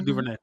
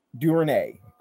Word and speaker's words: DuVernay: Ava DuVernay. – DuVernay.